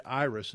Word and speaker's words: Jairus: Jairus is really how you say his name.